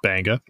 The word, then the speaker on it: Beqa: It's spelled Beqa in case you're looking it up, but pronounced Banga.